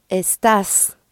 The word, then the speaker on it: estás: And to say you are, again, you start by getting the stem est, E-S-T, and this time you add an A-S, and the A takes an accent, estás, estás. – Right, with the accent on the second syllable, estás.